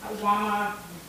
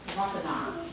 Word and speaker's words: Wampanoag: Later, Squanto helped the Pilgrims make a peaceful treaty with the Wampanoag. How you say it? Wampanoag tribe.